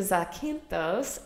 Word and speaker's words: Zakynthos: And I really liked, we went to Zakynthos is that how you say it? Yeah. Okay. So we went to Zakynthos and it was really nice because it was really more of a naturey place.